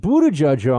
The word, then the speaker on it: Buttigieg: Fox has Poot, uh, uh Pete Buttigieg. Sorry for mispronouncing his name. Pete Buttigieg on to represent uh, the left wing point of view.